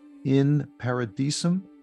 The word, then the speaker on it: In Paradisum: It's called In Paradisum.